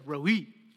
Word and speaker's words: Roi: The Hebrew word here is, or the Hebrew phrase, or the Hebrew name is Adonai Roi. Roi. You have to pronounce the E there. Roi.